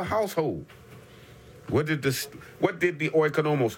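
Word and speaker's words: oikonomos (οἰκονόμος): The Greek word is oikonomos. Oikonomos. Spelled O-I-K-O-N-O-M-O-S. Oikonomos. I spell it again. O-I-K-O. N-O-M-O-S, stewards, oikonomos, and it literally means house manager.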